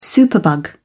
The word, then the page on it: superbug: British English